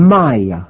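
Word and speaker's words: Maia: Maya.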